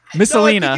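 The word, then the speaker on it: Messalina: It was I'm gonna get this name wrong, but I heard it once. Valeria Missalina. Missalina. – You got it right.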